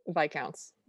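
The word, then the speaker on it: viscount: How is it said? – Viscounts. – Viscounts. Okay. So I know how to spell it, which is why I can't say it.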